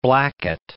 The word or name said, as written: Blackett